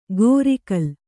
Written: ಗೋರಿಕಲ್

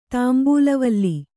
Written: ತಾಂಬೂಲವಲ್ಲಿ

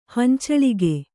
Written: ಹಂಚಳಿಗೆ

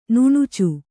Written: ನಾಣ್ಚು

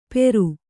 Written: ಪೇರು

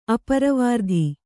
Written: ಅಪ್ರವೃತ್ತಿ

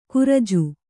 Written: ಕುರಿಜು

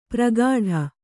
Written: ಪ್ರಗಾಢ

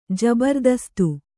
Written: ಜಬರದಸ್ತ್

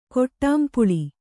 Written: ಕೊಟ್ಟಾಂಪುಳಿ

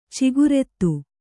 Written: ಚಿಗುರೆತ್ತು

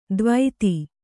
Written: ದ್ವೈತಿ

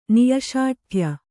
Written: ನಿಶ್ಶಾಠ್ಯ

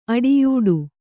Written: ಅಡಿಯೂಡು

